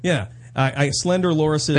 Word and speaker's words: loris: They were hiding two slender lorises. Is that how you pronounce it? They're like little lemurs, like little monkeys. – Loris? – L-O-R-I-S.